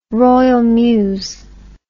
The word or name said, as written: Royal Mews